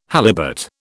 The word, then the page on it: halibut: British English